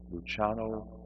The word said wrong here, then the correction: Lanciano: Lanciano? Do you know the pronunciation of that? Lanciano, thank you.